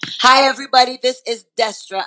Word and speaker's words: Destra: Destra.